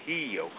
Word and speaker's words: Rio: Americans say Rio, but Rio. It's the H sound in Olá Bela. It's the H sound here with the R, so it's Rio de Janeiro.